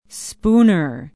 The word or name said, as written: spooner